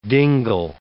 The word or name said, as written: dingle